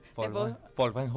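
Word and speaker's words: Folkwang: especialización en Estudios Superiores de Composición Coreográfica en Folwang, no sé cómo se pronuncia. – Folwang Hochschule, en Alemania.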